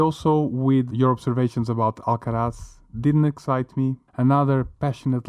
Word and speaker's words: Alcaraz: I can see Alcaraz. Is that how you pronounce it, Alcaraz? – Exactly.